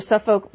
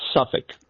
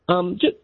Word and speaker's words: Suffolk: Suffolk, Virginia. I think I pronounced that wrong. An independent. Good morning. – Hey, good morning. Good morning. Thanks for having me. And yeah, it's Suffolk, Virginia. – Suffolk.